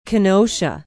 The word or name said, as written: Kenosha